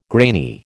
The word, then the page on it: grainy: American English